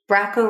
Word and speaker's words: Bracco: So in October, we have Bracco Rhymes with Taco.